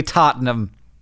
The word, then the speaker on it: Tottenham: Two goals to push Liverpool into the finals of the Champion Leagues against Ajax or possibly Tottenham.